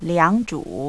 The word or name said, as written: liangzhu